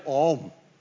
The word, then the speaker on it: Aum: It is actually pronounced A-U-M, OM.